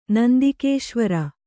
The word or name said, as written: ನಂದಿಕೇಶ್ವರ